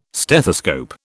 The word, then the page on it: stethoscope: British English